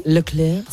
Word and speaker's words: Leclerc: Do you know the F1 driver, Charles Leclerc? I hope I'm saying that correctly. – Leclerc.